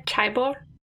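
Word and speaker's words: chaebol: A chaibol.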